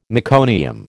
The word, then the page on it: meconium: American English